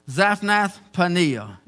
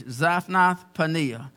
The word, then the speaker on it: Zaphnath-Paneah: Zaphnath-Paneah. It is Zaphnath-Paneah. Zaphnath-Paneah.